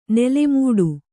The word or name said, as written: ನಾಲ್ಮಡಿ